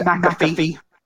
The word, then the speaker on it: McAfee: His name is pronounced McAfee.